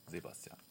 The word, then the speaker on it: Sebastian: Ist das Sebastian oder Sebastian? – Sebastian.